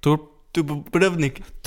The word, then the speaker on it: Dubrovnik: Drubovnik. Dubrovnik. Dubrovnik. Det er kroatisk udtale.